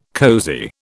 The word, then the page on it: cosy: British English